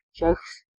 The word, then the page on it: Czech: [ʧɛk]; /tʃɛk/